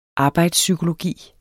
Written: arbejdspsykologi

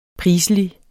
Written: priselig

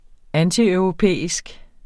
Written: antieuropæisk

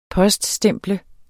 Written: poststemple

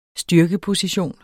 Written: styrkeposition

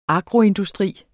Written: agroindustri

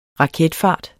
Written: raketfart

